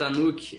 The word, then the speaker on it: Anuki: Então, o I Got You que é uma, é, aí uma parceria com a Anuki. Me corrija se eu estiver pronunciando errado, tá certo? – Tá certo, Anuki.